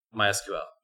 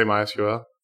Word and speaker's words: MySQL: So how do you say MySQL? – MySQL. – Say MySQL? That's weird to me. – I say MySQL. – I was taught like both ways depending on the mood of the professor that day.